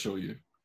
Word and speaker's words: shoyu: Is that shoyu? Let me see it again. Make sure before I say it wrong. Yeah, that's shoyu. So wasabi soy sauce flavored Doritos for whoever wants them.